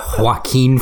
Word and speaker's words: Joaquin: Do you mean Joaquin Phoenix? – Is that how it's said? – It's a soft J, yeah.